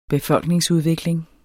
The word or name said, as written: befolkningsudvikling